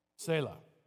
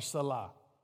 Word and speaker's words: Selah: Selah. S-E-L-A-H. Selah or Salah. However you want to pronounce it, Selah.